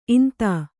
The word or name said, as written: ಇಂತಿ